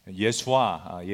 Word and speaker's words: 예수아: 예수아, 예수라는 이름의 오리지널 발음인데 그 발음처럼 우리도 예수님의 본 모습을 배우고 쫓아가자 그런 취지입니다.